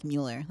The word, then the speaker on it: Mueller: It is spelled kind of like Mueller, like you pronounce it, M-U-E-L-L-E-R.